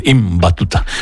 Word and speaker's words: Ibn Battuta: Hay que pronunciar bien, Ibn Battuta.